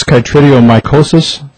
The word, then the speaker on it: chytridiomycosis: Is this chytridiomycosis? Am I saying that correctly? – Yes, okay. That's correct.